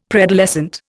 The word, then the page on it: preadolescent: Indian